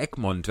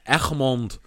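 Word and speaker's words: Egmond: Oder Egmont, wenn man es weniger äh, anbiedernd und affig aussprechen möchte. – Echmond am äh, Und Echmond, äh, wie es ja korrekt ist, machen wir uns nichts vor.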